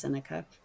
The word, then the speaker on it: Seneca: I'm just assuming I'm saying his name right. It's spelled Seneca. I think it's pronounced Seneca. He was a philosopher. – Sounds right.